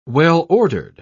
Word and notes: well-ordered: British English

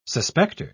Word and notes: suspecter: British English

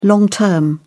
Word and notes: long term: British English

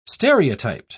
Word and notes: stereotyped: British English

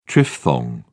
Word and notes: triphthong: British English

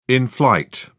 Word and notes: in flight: British English

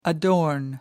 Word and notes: adorn: American English

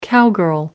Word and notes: cowgirl: American English

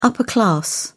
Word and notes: upper class: British English

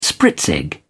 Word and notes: spritzig: British English

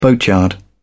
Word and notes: boatyard: British English

